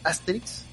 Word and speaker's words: Asterix: Se pronuncia Asterix. Yo siempre le he dicho Asterix. Y algunos le dicen y la pronunciación correcta es Asterix. Ahí es donde está el maldito acento.